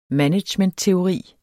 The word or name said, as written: managementteori